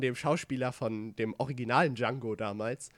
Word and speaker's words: Django: ähm, äh, wo er quasi mit dem redet und ihm erklärt, dass er Django heißt, mit dem D und dass das D äh, stumm ausgesprochen wird.